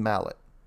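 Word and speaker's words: Malet: Now, it's probably pronounced Malay, but I'm going with the English pronunciation. William Mallet.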